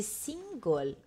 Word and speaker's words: single: Ecco, ragazzi, single. Usiamo è, è, la pronuncia è come l'inglese, non pronunciamo come italiano.